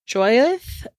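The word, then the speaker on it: Jyothi: so on October 7th of 2019, Shankar killed his wife, and I believe it's pronounced Joyeth. It's J Y O T H I, and she was 46, and their 16 year old daughter, Jari, in their apartment in Roseville.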